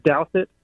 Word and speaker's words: Douthit: But the last name is Douthat, and it is spelled D-O-U-T-H-I-T.